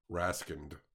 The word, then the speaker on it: Raskind: With a D at the end, silent D. – Raskind.